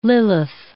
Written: Lilith

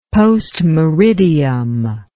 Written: post meridiem

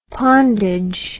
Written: pondage

